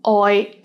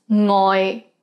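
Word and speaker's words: oi (愛): For example, oi is sometimes pronounced as ngoi mistakenly when the speaker thought the standard form had an ng consonant.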